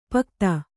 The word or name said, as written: ಪೋಕ್ತ